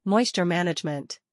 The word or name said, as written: Moisture Management